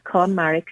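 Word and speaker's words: KonMari: I think it's Conmurray. How am I pronouncing that wrong, actually? Am I? Conmurray. Is it Conmurray? – No, it's fine. It's fine. Thanks for having me on. It's Conmurray Consultant, yeah.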